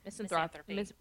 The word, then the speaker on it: misanthropy: It's ends in a Y. – Misanthropy.